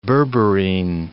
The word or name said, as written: berberine